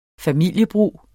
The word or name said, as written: familiebrug